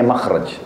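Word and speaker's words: makhraj (مخرج): Bahasa Arabnya itu mim kha ra jim, bacanya makhraj. Kenapa digunakan kalimat itu?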